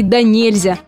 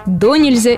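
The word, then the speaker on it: донельзя: О самом обыкновенном наречии «да нельзя». Или, как бывает, его произносят «до нельзя» или даже «да нельзя». Как же правильно? Вариант здесь единственно верный по всем словарям. «Да нельзя» с ударением на второй слог.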